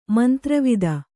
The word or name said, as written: ಮಂತ್ರವಿದ